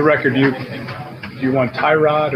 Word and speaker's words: Tyrod: now Tyrod Taylor. Um, basically, there was this whole like this thing that came out um, about how his name is actually pronounced Tyrod uh, instead of Tyrod, and I'll let uh, Bobby play that clip real quick.